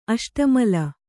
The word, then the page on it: ಅಷ್ಟಮಲ: aṣṭamala